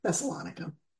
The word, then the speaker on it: Thessalonica: Or Thessalonica, I always say it wrong, Thessalonica.